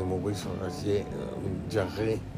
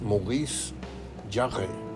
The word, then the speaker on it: Maurice Jarre: tenía discos de Maurice Jarre, así se dice, se escribe Jarre, pues, pero yo trato de desempolvar mi francés betabelero y es Maurice Jarre, tómala.